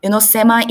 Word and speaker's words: Sema: Sema is another pronunciation of Samo. – Okay. – In Turkey, okay, they pronounce as Sema.